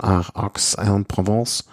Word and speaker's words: Aix-en-Provence: Und die Etappe nach Avignon von Aix-en-Provence. Ich glaube, so spricht man das aus, ne? Aber ich denke mir immer, es klingt so falsch, aber es ist ja richtig.